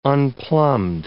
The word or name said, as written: unplumbed